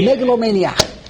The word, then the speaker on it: megalomaniac: How do you say it? – Megalomaniac.